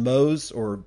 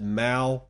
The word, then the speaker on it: Moe: Is the way you pronounce his last name Mo. I used to pronounce it Mao, but it's not. It's actually like Mow the Lawn.